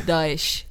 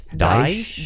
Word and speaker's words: Daesh: Daesh. – Daesh? – Daesh. – Spell it for us. – D-A-E-S-H.